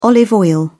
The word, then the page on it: olive oil: British English